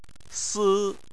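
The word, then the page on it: si: tone 1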